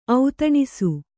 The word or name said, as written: ಔತಣಿಸು